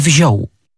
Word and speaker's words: wziął: albo nawet wymawiamy o, tak jak we wziął.